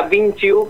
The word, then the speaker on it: Vinci U: Sí, sí, se pronuncia Vinci U como Leonardo, claro, da Vinci, pero se escribe Vinci U.